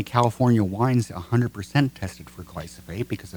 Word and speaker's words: glyphosate: Glyphosate.